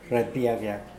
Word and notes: rapiakiak: Mokilese

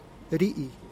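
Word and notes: rii: Teotitlán del Valle Zapotec

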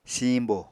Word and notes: siiñbo: Apatani

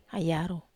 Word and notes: ai yarou: Wamesa

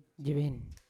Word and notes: jevena: Yawa

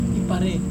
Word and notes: iparei: Serewen

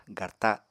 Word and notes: garta: apatani

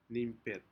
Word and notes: nimpet: Aneityum

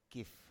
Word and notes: kif: Biak

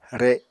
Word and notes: re: Apatani

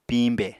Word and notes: piñbè: Apatani

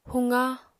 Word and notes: huqa: apatani

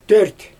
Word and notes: dört: Xyzyl

